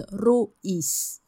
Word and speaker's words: ruis: 嗯，然后这个叫做 Lucy Leba 对对对，我们芬兰文就是每一个字都发音，就其实还蛮直白蛮直接的、所以 R-U-I-S 就是 Ruiz 这样